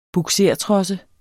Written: bugsertrosse

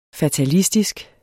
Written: fatalistisk

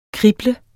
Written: krible

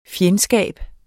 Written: FJENDSKABERNES

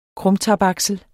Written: krumtapaksel

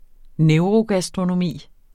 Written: neurogastronomi

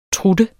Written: trutte

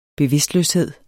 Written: bevidstløshed

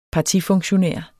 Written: partifunktionær

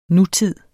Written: nutid

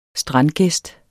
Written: strandgæst

